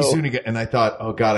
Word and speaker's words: Zuniga: He introduces Daphne Zuniga like is that how you say her name? Zuniga? – Yeah, Zuniga? I think so, yeah.